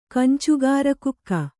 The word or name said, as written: ಕಂಚುಗಾರಕುಕ್ಕ